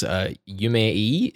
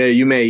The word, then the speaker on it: Yume-E: I think I read that online that you, for one of your last singles, Yume-E is the way it's pronounced. – Yeah, Yume-E, yeah.